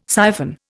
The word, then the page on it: siphon: Indian